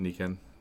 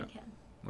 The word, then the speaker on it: Nikken: N-I-K-K-E-N. And I always tell people it's like your knee. – Yeah. – And Barbie's boyfriend. – Okay. – All right? – Yeah. – That's how we pronounce it.